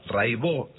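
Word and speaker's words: Raybaud: Buenísimo, Cata Raibó. Se escribe Raibaus, métanse, búsquenla, Rai con Y, Baut con B larga. Catalina Raibaut, Raibó se dice, y bueno, cotidiano son 10 canciones, hay un documentalito muy lindo.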